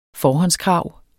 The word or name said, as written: forhåndskrav